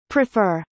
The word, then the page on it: prefer: prifə’ː r; プリファー